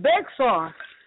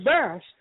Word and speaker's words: Bexar: That's just like people saying Bexar. They don't know. You don't know the area until you pronounce it as Bash.